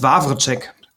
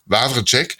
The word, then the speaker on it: Wawczek: Der Jens, wie wird's denn ausgesprochen? Jens Wawczek. – Wawczek, der ist die Stimme von, ich habe es heute noch nachgeschaut, Ben Stiller, kann das sein?